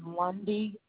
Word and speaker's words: Lundy: My first name is Sharday, C-H-A-R, D as in dog, E. And my last name is Lundy, L-U-N, D as in dog, Y.